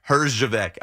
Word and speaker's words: Herjavec: Herjavec.